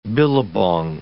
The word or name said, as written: billabong